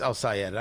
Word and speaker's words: El-Sayed: I think it's really pronounced El-Sayed. I do want to say it properly.